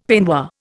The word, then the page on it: peignoir: Indian